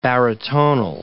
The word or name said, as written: baritonal